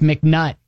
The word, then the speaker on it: McNutt: Judy checks in and says, Kelly, yes, McNutt. – Thank you. – Okay, rhymes with butt.